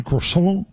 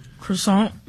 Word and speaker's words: croissant: All right, on our calendar for the weekend, National Croissant Day. And that's the way you say it. Can you say it with me? Croissant? – Croissant.